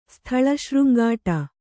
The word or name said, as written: ಸ್ಥಳಶೃಂಗಾಟ